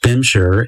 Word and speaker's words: Bimshire: It's called Bemshaw Blues, not to be confused with Thelonious Monk's Bemshaw Swing. Bemshaw, or as it's more commonly pronounced, Bimshire, is a nickname for Barbados.